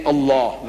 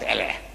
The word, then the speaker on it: Allah: From E-L-A-H, now they got A-L-A-H. Only thing I'm saying, you must pronounce it the way I ask you to pronounce. Allah, you can spell it, but say Allah, not Allah.